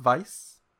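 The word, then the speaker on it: Weisz: That's the correct pronunciation, Weiss, I believe.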